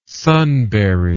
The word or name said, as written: sunberry